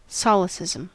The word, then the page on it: solecism: American English